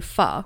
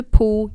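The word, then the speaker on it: phở: Vi har ganska mycket nudelsoppa, vår nationalrätt är ju fö och det uttalas f, f som mm. fö. Och inte po, inte på, inte någonting annat.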